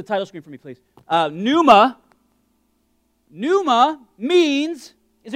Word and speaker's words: pneuma: It's pronounced pneuma, not panuma. I was calling it panuma for many years, and then somebody who knows Greek better than I said, the P is silent.